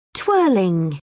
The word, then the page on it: twirling: British English